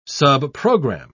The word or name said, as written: subprogram